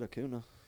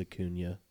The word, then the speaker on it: Acuña: I said Acuña. – It's Acuña.